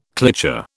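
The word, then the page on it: cliché: British English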